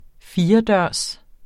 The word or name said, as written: firedørs